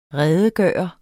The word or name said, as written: redegøre